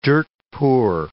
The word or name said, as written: dirt-poor